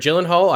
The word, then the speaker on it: Gyllenhaal: Is not silent. It's Jake okay. Gyllenhaal. – So I wasn't sure about this because I've heard Gyllenhaal, I've heard Gyllenhaal, I've heard all kinds of ways.